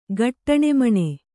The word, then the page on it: ಗಟ್ಟಣೆಮಣೆ: kannada